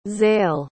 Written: Zale

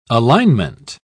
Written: alignment

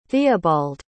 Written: theobald

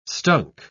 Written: stunk

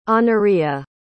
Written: honoria

